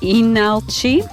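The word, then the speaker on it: Ilnalchi: And from South Korea, we heard from Inalchi. They have an L before that, but the first L is silent. Don't say that first L.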